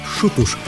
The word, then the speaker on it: Xutos: А произносится «шутуш» практически одинаково.